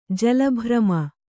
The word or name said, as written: ಜಲಭ್ರಮ